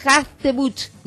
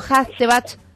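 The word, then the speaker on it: Hartzenbusch: ¿Ves? Hachtebuch, no Hachtebach. Es que yo, estaba pronunciando mal, Sergio. Luego la gente se va a otra calle, ¿no? Puede ser. – Sí, entre U y A, así va la pronunciación, entre la U y la A, sí.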